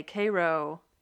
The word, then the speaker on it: Cairo: Want to move on to Ethan and Olivia's house in Cairo, which I would just like to say, Cairo, Georgia, is spelled Cairo like Egypt, but you say it like the sweet syrup.